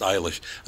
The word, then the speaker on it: Eilish: It doesn't say how they pronounce it, but her name is pronounced Eilish. Another guy, K-E-L-C-E. How would you pronounce that? – Kelsey? – Kels. – K-E-L-C...